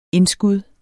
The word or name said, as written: indskud